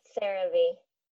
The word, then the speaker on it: CeraVe: And then you're the owner of the Rave. Is that how you say it? – CeraVe. – CeraVe. Dang it.